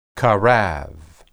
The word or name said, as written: qarab